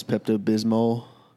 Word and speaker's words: Pepto-Bismol: And I took a Pepto-Bismol because those normally help me. – For those that don't know, Jenny calls Pepto-Bismol for some reason. – Oh, wait, so it is with the L.